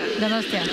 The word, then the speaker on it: Donostia: Donostia.